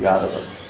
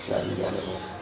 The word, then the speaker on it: Yadava: So it becomes with a long A, Yadu. And then you change it to the up to clinch it. So it ends in a U, so you have to change that U to an A, V, and then add an A, A, and the Yadava. Yadava.